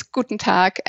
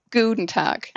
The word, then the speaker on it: Guten Tag: You stretch the U too much. So it's Guten Tag and not Guten Tag.